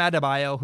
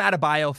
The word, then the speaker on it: Adebayo: Bam Adebayo, thank goodness he spells his name like you would. If you phonetically spell Bam Adebayo's name, you'd go, okay, that makes sense. That's what I would expect.